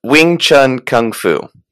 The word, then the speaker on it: Wing Chun Kung Fu: Wing Chun Kung Fu.